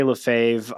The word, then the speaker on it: Lefevre: Yeah, I get that a lot. We say Lefevre. I get Lefevre. It's a French name.